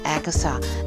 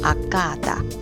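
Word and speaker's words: Agata: 英文的发音虽然是 Agatha，但它的名字其实是 Agada。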